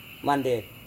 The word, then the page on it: mandep: Serewen